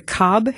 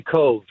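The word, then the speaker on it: Cobh: And for anybody who really has family or roots in Ireland, anytime you mention, am I saying that correctly, the Cobb Heritage Center? – It's actually Cove. – Cove. – Like a Lake Cove, yeah. – Like Siobhan, okay. – Yeah, like Siobhan, exactly.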